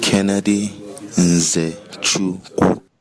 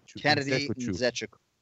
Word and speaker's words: Kennedy Nzechukwu: Kennedy in Zechiku. Kennedy Nzechukwu. – Kennedy Nzechukwu. So there you have it.